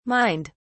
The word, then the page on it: mined: British English